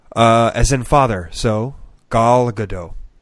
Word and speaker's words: Gadot: It's Gal Gadot? – Yeah, Gal Gadot. – Or is it Gadot? How is the O? Is it a long O or a short O? – It's the O as in body.